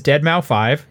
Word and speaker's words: deadmau5: He also came up and he said, "Hey, I'm you know my stage name is Dead Mouse Five. Yes, that's the way you're pr- supposed to pronounce it."